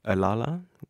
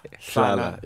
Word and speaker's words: Slala: I love one that is called, I'm sorry if I'm saying this wrong, Alala? – Slala.